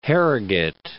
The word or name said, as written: Harrogate